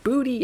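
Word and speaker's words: Boutiette: It's Boutittee, but they said pronounced Booty.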